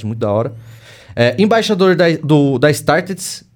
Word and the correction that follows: StartSe: da Startse, Startse? Falei certo? Isso.